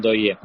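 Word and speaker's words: Ndoye: У Денан Дойе. Так мне больше нравится, как-то ближе к нам это произношение его фамилии.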